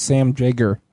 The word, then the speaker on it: Jaeger: Are you sure it's not Jaeger? – What is it? – I'm sure I heard him pronounce it whenever I talked to him last week. It's Sam Jaeger.